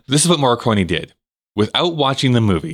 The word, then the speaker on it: Morricone: Ennio Morricone, is that am I saying that? Ennio Morricone.